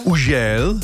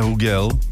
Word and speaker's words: Hugel: Francouzský DJ Užel, takhle se to přesně vyslovuje, Užel, ne Hugel, ani Hužel, Užel.